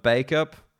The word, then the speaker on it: Bacup: It's spelled B-A-C-U-P, but definitely pronounced Bacup because